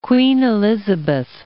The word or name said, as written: Queen Elizabeth Islands